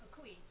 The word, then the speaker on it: kukui: Is that pronouncing that right? – It's a kukui. It's called Koabees, K-O-A.